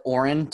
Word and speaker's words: orin: I see it's O-R-I-N. How do you say that? – So people pronounce it Orin. I'm not actually sure why they call it Orin as opposed to urine.